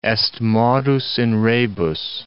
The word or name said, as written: est modus in rebus